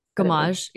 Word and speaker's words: gommage: And then you get a travel size of the rice wash, which is a really, really beautiful gommage. Is that how you say it? Gommage, yeah. Gommage, yeah.